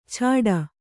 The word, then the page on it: ಛಾಡ: chāḍa